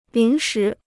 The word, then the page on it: 临时: lín shí